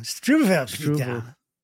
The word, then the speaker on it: Struwwelpeter: And the name of the character in the story was Struwwelpeter. I don't know how you would pronounce that. Struwwelpeter. Struwwelpeter. – The W's are V's. – Oh, Struwwelpeter.